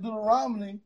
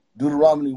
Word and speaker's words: Deuteronomy: Deuteronomy 1-6. – You know that's not how you say it, right? – Oh, my goodness gracious. – It's Ron. Ronomy. Not Romany. Ronomy.